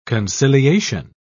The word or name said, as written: conciliation